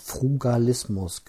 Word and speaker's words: Frugalismus: Ich ähm, bin nämlich gebeten worden, mal über das Thema ähm, Frugarismus zu sprechen. Habe ich das überhaupt richtig ausgesprochen jetzt? Ähm, muss mal ganz kurz gucken. Ich finde das ein ganz komisches. Ähm, Wort. Frugalismus, genau.